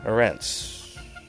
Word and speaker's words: Ourense: O-U-R-E-N-S-E. Yeah, Urense. Yeah. Rhymes with Hortense, I guess.